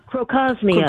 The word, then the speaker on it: Crocosmia: Another one that I have just fallen in love with, and it's pretty inexpensive, that Croxemia. Did I say that right? Croxemia? – Crocosmia. – Crocosmia.